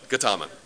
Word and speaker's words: Gautama: Siddhartha Gautama, does anyone know how to really say that? Gautama? Just so that I'll stop annoying you with butchering it. Gautama? Thank you. You're from San Francisco. You're cultured, right? You know it.